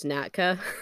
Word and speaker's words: NATCA: It's always like I have always heard it referred to as NATCA.